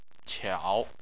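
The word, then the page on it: qiao: tone 3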